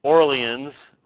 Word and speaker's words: Orléans: From 1523 to 1528, he is engaged in study there in Paris, and then in 1528 he goes to Orléans, which we would, in our language, pronounce as Orleans, or as in New Orleans, or New Orleans, if you go down there and actually talk to those folks.